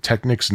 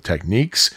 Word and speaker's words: Technics: I said Technics instead of Techniques. If you are cringing because of that, I got news for you. I've seen some commercials that pronounce it Techniques and some that pronounce it Technics, so pick your poison.